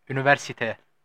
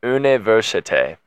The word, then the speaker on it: Université: Université. – Say again. – Université. – Université. – Sini. – Sini. – Sivi. – Sivi. – Yorum. – Yorum. – Nice. – Sivi Yorum. Illinois Université Sini Univern.